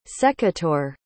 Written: secateur

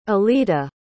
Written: elita

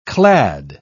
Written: clad

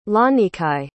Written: lanikai

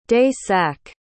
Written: day-sack

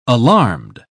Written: alarmed

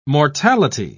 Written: mortality

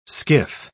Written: skiff

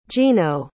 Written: geno

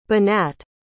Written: benat

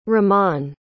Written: rahman

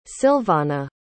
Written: Sylvonna

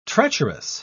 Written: treacherous

